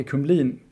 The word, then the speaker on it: Kumlien: Men sen så skriver han om Acke Kumlin uttalas det va? – Mm. Även om mm. det stavas Kumlien.